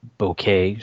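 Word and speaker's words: bokeh: It's pronounced a bouquet, so it's just the way the lenses lined up.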